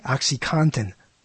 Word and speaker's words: OxyContin: But when I, OxyContin, I said it correctly, didn't I? OxyContin. right? – Is it? Cotton. Yeah.